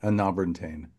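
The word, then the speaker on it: Enabran Tain: Anobrin Tain.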